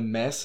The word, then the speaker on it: Mads: Wir können auch gerne ihn weiterhin Mads nennen in der deutschen Form. Aber ich meine, Mads ist die richtige Aussprache im Dänischen.